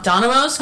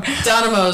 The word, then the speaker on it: Domino's: I think Domino's cool. – Domino's. – Wait. Domino. – Am I saying it right or wrong? – Donimo is wrong.